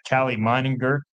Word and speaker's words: Meininger: And we've got Callie Meininger joining us on the show.